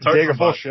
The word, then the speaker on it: Degerfors: Degerfors, who are third. – Degerfors, yeah.